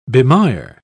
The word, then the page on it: bemire: British English